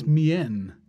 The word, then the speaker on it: mien: That was Black Habit by Mien, spelled M-I-E-N. – I think it's Mien.